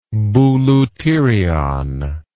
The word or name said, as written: bouleuterion